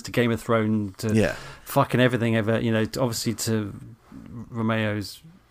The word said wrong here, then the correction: Romero: Is that how you say his name? – Romero.